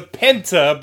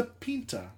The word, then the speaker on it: Pinta: I believe that when you're speaking someone else's language, it is only culturally fair to pronounce it with their accent. How ethnocentric of me would it be to go, no, I'm going to anglicize everything because America. No. It's the pinta.